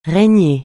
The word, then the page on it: régner: francais